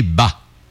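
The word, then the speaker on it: ba (八): En mandarin, la prononciation du numéro 8 est ba. Okay. Ça se dit ba.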